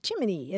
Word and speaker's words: Gemini: And apparently in 1965, UPI, which was the wire service back then, ran an article that said, regardless of what the dictionary says, the Federal Space Agency's official pronunciation for its new man-in-space program, Gemini, is Jiminy, as in Jiminy Cricket.